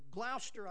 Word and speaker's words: Gloucester: Then Hooper was offered the position of bishop at Gloucester, or Gloucester, I guess is how you really pronounce that there.